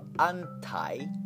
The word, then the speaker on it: anti: Think of anti, rhyming with tie rather than T, or eight, rhyming with late rather than let, eight.